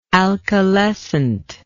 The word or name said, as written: alkalescency